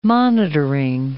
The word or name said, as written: monitoring